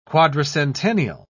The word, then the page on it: quadricentennial: British English